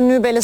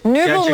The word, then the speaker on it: Nübel: Nübel mi demeliyiz? Ayaklarına... – Nübel mi? – Nübel aslında da Nübel. İşte Nübel Türkçe'de okuyor. Nübel aslında. – Peki. – Ama telaffuzu öyle, ama neyse problem değil o kadar.